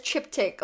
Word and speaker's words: triptych: Oh my God, I've been saying it wrong. I've been saying triptych because... – I could be wrong, though. – It's triptych. Okay, hold up.